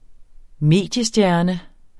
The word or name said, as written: mediestjerne